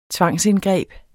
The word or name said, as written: tvangsindgreb